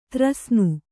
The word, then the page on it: ತ್ರಸ್ನು: Kannada